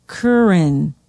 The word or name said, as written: Curran